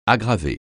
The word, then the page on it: aggraver: French